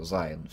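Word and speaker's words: Seyn: То есть в немецком языке, в старых источниках, Zein писалось не через I с точкой, а через Y. Но оно читается, понятно, так же, это Zein все равно.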